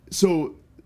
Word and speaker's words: Solange: I think that's how you pronounce it. Is it Solange? – Solange.